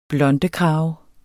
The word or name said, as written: blondekrave